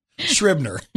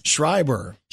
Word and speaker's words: Schreiber: I don't know how to say his name. – Schreiber. – What is it? – Schreiber. There's Schreiber. No N in there.